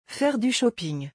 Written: Faire du shopping

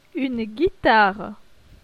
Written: Une guitare

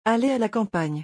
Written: Aller a la campagne

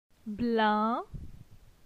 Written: Blin

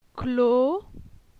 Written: Clo

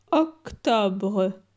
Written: Octobre

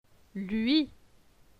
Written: Lui

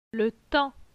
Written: Le temps

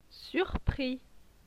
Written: surpris

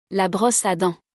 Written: La brosse a dents

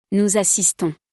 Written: nous assistons

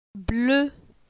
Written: Ble

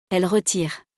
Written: elles retirent

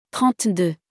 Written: 32

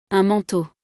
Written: Un manteau

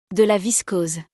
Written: De la viscose

